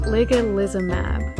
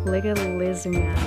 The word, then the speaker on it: ligalizumab: Ligalizumab. There's so many L's. Ligalizumab.